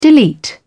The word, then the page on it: delete: British English